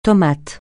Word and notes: tomate: francais

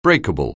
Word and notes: breakable: anglais

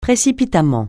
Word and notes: précipitamment: francais

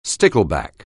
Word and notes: stickleback: anglais

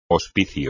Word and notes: hospicio: espagnol

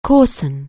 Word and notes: coarsen: anglais

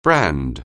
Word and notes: brand: anglais

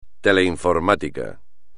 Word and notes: teleinformática: espagnol

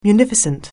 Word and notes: munificent: anglais

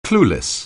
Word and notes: clueless: anglais

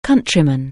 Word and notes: countryman: anglais

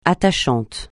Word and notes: attachant: francais

